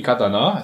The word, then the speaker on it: Katana: Auf der neuen Neuzugi Katana. Es wird jede Silbe gleich lang ausgesprochen.